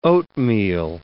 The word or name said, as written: oatmeal